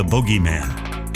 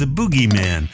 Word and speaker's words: bogeyman: I'm Canadian, so up here we call him the bogeyman. Down south, he's referred to as the boogeyman. Well, I don't remember Maverick splashing any boogies, so I'm going with the Canadian pronunciation.